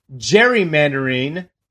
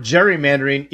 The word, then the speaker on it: gerrymandering: Interestingly enough, we use the word gerrymandering even though it's actually pronounced gerrymandering, named after him.